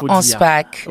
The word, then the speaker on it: Anspach: Est-ce que je le dis comme il faut dire? – Boulevard Anspach.